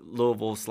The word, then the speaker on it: Louisville: And you're from, I'm going to hopefully say this right, Louisville. – That's pretty close, Louisville. Yeah. – Louisville.